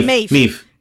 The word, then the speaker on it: Maeve: Maeve. – Vale. – Sí, Maeve.